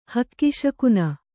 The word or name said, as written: ಹಕ್ಕಿಶಕುನ